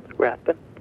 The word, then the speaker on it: Rathbun: Rathbun. – How do you spell it, John? – R-A-T-H-B-U-N.